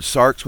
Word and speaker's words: sarx: But the interesting thing in the scripture is that the flesh is sometimes translated, the Greek word is sarx, S-A-R-X, you could pronounce it sarx, as opposed to psuche, which is soul, and spirit, which is pneuma in the Greek, or ruach in the Hebrew.